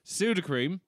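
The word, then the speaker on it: Sudocrem: Speaking of, fun fact, pseudo cream is... – It's gone now, I hear. – No, it's Irish. And the reason it's called pseudo cream is because it is the Dublin pronunciation of soothing cream.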